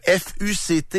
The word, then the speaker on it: FUCT: Le FUCT, il est vexant parce que quand tu le prononces, ça fait référence à un gros mot, genre un sac finalement.